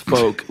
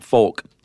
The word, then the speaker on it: folk: In fact, up until about a year ago when someone wrote in and said, you stupid, it's pronounced folk, like F-O-K-E. – Folk. – And not folk.